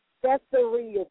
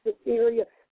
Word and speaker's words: Caesarea: Caesarea. Some people say Caesarea, some say Caesarea. So whatever your pronunciation is, that's good.